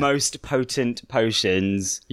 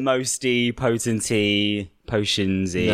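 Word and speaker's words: Moste Potente Potions: Let's pronounce it the proper way. – Yeah. – Most potent potions. – Yeah. – Not mosty, potenty, potionsy.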